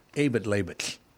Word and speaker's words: Eyjafjallajökull: And it literally is pronounced Eyjafjallajökull. You have to do the sounds like after you're done talking on a walkie-talkie and it makes that click.